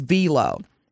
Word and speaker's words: Wilo: Now, the German company is spelled W-I-L-O, but the actual pronunciation is Velo.